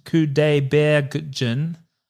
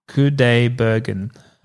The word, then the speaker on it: Kudaibergen: Ara showed me one a couple of years ago, which was pretty good, but apparently this guy, Dimash Kudaibergen, I think... – Sounds right. – I said that wrong. – I got that one right. – I added an extra half syllable for some reason.